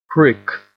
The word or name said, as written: prick